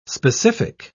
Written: specific

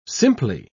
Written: simply

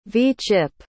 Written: v-chip

